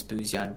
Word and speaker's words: Buzyan: Um, my handles is P and then my last name is Buzyan, B U Z Y A N.